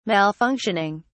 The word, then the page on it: malfunctioning: British English